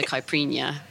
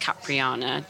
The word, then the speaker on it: caipirinha: So it's not pronounced Capriana, just so anyone knows.